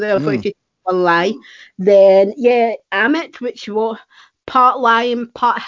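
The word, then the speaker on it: Ammit: However, it was adamant. Is that how you pronounce that? Adamant? Amit, yeah. Sorry, the damned souls, not Anubis.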